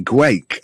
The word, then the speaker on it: Guake: G-U-A-K-E, Gwake, I think it's pronounced, isn't it? – Yeah.